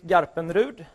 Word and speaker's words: Garpenrud: So the American people, they usually say Garpenrud or Garpenrud, and I kind of like that. So, but I figured I would explain myself as the real Swedish pronunciation here today, but yeah, so that's who I am.